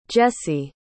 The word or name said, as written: Jessee